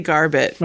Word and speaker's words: Garbutt: Maybe that's not even how you pronounce it, but yeah. Garbutt? – Yeah, say Garbutt.